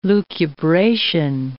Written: lucubration

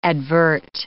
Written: advert

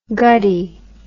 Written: guddee

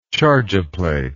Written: charge a plate

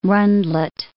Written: rundlet

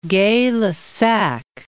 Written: Gay-Lussac

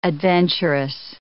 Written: adventurous

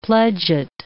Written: pledget

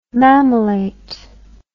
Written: mamillate

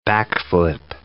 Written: backflip

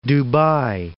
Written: Dubai

